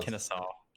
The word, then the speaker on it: Kennesaw: No, it's just Kennesaw. Kennesaw State.